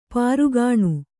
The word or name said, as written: ಪೊರಗಣ